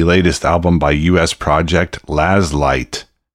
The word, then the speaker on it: Lazleitt: The name of the band is actually spelled L-A-Z-L-E-I-T-T, but pronounced Laz Light.